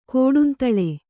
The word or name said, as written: ಕೋಡುಂತಳೆ